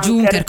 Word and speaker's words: Junker: scaricatevi se volete l'app Junker mi sembra comunque sicuramente ho sbagliato la pronuncia Junker, Junker, Junker come no, volete comunque ah, Junker. a Junker giusto perché viene da Junker, Junker spazzatura sì